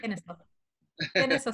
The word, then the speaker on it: Kennesaw: Kennesaw State.